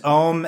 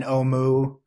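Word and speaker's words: Ohmu: which is there's Om and Omu, you know, either one's fine.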